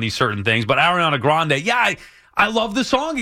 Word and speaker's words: Grande: Ariana Grande. – Grande. Yeah, there you go. Ariana Grande.